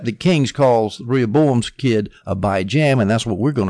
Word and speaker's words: Abijam: And Rehoboam had a son, and he was named Abijam, J-A-M. I'm going to pronounce it weird, just to emphasize the jam, Abijam.